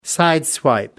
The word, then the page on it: sideswipe: American English